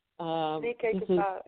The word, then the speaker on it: Ne Kelke Pa: This is, I don't know if I'm pronouncing it correctly, Ne Kelke Pa. – Ne Kelke Pa, yes.